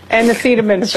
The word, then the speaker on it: acetaminophen: Acetaminophen.